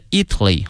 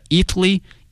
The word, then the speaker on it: Italy: 意大利这个词啊，同学们要小心了，它的名词和它的形容词呢，这个发音同学们一定要小心了。意大利呢是 Italy 啊，国名。如果说意大利的或意大利人叫 Italian，哎，所以说这个发音还是有区别的。 Italy, Italian.